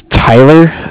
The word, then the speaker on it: Tyler: My name is Tyler, T-Y-L-E-R. I'm calling with Cardiff. It's spelled C-A-R-D-I-F-F, as in Frank, Frank.